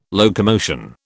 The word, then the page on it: locomotion: British English